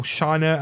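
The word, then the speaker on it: Scheinert: That's Daniel Kwan and Daniel Scheiner, I think it's pronounced.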